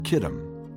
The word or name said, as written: Kittim